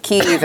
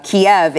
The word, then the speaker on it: Kyiv: I'm actually saying the Ukrainian pronunciation of the capital, Kiev, is actually the Russian pronunciation of that city.